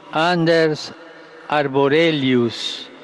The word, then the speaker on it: Anders Arborelius: Monsignor Anders Arborelius, vescovo di Stoccolma, Svezia.